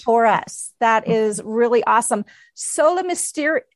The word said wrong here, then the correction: Mysterium: Is that how you pronounce it? – Sola Mysterium. Yes. – Mysterium. Thank you.